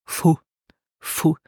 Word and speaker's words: von: And so here we add the preposition von, von, but with the N rule, we will pronounce it fu, fu.